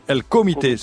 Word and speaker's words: El Comité: Alors, groupe cubain, vous êtes 7, l'accent tonique c'est El Comité. El Comité, comment dois-je dire pour ne pas faire de bêtises? – Voilà, ça c'est El Comité. – El Comité, sur le haut, d'accord.